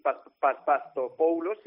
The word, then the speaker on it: Papastathopoulos: De hecho, creo que hoy se va a anunciar el fichaje de un central griego que se llama Sócrates Papastathopoulos, si lo he dicho bien.